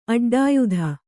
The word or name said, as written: ಅಡ್ಡಾಯುಧ